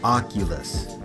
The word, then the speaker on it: Oculus: Okay, on a related note, the Oculus Rift. – Wait. How do you say it? Octopus? Octagon? – No. Oculus.